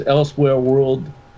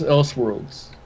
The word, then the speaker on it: Elseworlds: It posts, Joaquin Phoenix has officially confirmed to play Joker in DC's Elsewhere World. – Elsewhere World? – Yeah, that's what they put. So I was the first comment, and I know he saw it. And it's pronounced Elseworlds.